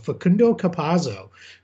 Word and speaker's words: Facundo Campazzo: And then a guy who not a lot of people may know a lot about, but Facundo Campazzo, who is one of the top guards for years.